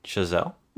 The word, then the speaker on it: Chazelle: So, this is the newest movie from Damien Chazelle, I believe how you pronounce it. I'm probably butchering that, so help me out with this pronunciation. – No, I think that's pretty good.